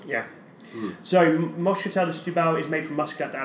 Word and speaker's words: Setúbal: Moscatel de Stubal. – De Stubal. – Stubal. – phonetically, that's basically spelled Setúbal. – Setúbal, yeah. – Moscatel de Setúbal.